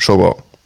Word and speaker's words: Shogun: Ganz kurz, das heißt Shogun. Genau.